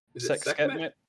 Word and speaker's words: Sekhmet: So, like I said, so Ammit, protecting the Lake of Fire, some have suggested may be linked to Skamet, I think that's how you pronounce it, due to her lioness Leonin characteristics and her role in protecting the Lake of Fire. So, Skamet might be... – Sekhmet? – Maybe. I don't know. S-E-K-H-M-E-T.